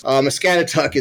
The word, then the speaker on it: Muscatatuck: I always say, you kind of say like, my cat is stuck, and it gets you pretty close. Muscatatuck is a Native American term.